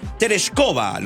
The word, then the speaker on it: Tereshkova: En 1963, Quique Bravo nos recuerda que Valentina Tereshkova, lo dije bien, ¿no? – Sí, está bien.